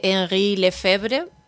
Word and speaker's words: Henri Lefebvre: Nos has mencionado en alguna ocasión la figura de Henri Lefebvre, no sé si lo he pronunciado bien. – Sí, muy bien.